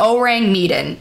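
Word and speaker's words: Ourang Medan: I think it's pronounced the Auring Meaden. Okay. It's O U R A N G M E D A N, and it's two words. Just call it the Om. So the Orang Medan mystery.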